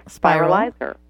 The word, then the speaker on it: spiralizer: Think of the word spiral. So it's a spiralizer.